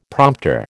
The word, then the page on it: prompter: American English